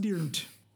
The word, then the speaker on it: Dirnt: We can't be the first people that have said his last name sounds like hitting a bass note. Durnt. – I like. It has to be somewhat intentional. – He's right? gonna pop up and be like, actually, it's pronounced Dirnt.